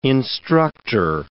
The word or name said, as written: instructor